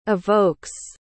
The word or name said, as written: evokes